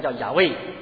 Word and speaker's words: 雅威: 然后呢，这个自由拥有是两个什么的事情，这是希伯来语 YHWH 四个辅音，没有元音，没办法发音的，到现在也不知道人这确切是怎么叫。那有人把它翻成叫雅威，那后来把它加上元音以后，人把它能读出来，这个字就是耶和华，中文耶和华。